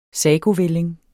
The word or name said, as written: sagovælling